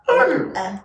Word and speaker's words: herb: Herb. – With an H that you can hear. – Herb.